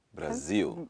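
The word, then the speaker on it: Brasil: So the word for the country of Brazil is pronounced... – Brazil. – Brazil. – Brazil. And if you're a Spanish speaker, you'd say that country. – Brazil. – Yeah. So it's kind of like, it's almost like a W.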